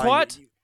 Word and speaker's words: kumquat: I heard a hard C. – No. It's a hard K. – Okay. Okay. – Yeah. You know what? I feel like kumquat.